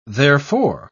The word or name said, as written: therefor